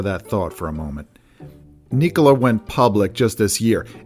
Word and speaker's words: Nikola: So a company called Nikola had an eventful week on Wall Street, and I'm going to try to stick with the pronunciation Nikola as much as I want to say Nikola.